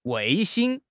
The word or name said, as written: 卫星